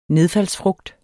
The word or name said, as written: nedfaldsfrugt